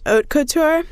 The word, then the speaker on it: haute couture: I think it's pretty good, and it's on Spotify, but quickly learned like the following day that it's pronounced haute couture. So, like oats.